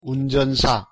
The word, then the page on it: 운전사: Korean